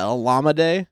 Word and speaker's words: Olamide: I'm pretty sure we're all set that Olamide is the correct way of pronouncing the first name.